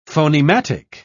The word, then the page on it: phonematic: British English